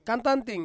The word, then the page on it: kantanting: Ansus